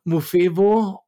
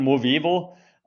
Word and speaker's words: Movevo: Was bedeutet denn Mofevo und was macht ihr? – Ja, zuerst gleich ein großes Lob. Du hast es gleich von Anhieb an richtig ausgesprochen. Movevo äh, in einem durch.